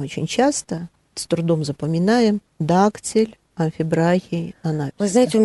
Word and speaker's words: дактиль: mm Дактиль. Ударение на первый слог значит, будет ударение на первый.